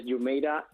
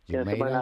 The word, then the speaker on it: Jumeirah: Es fácil, es Jumeirah International. – Jumeirah International. – En inglés. – Bueno, es que como es una mezcla entre árabe e inglés, ¿no? ¿O el Jumeirah es inglés?